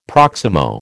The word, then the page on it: proximo: American English